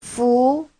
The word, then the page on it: fu: fu2